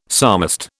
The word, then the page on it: psalmist: British English